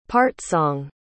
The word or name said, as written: part-song